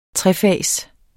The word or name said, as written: trefags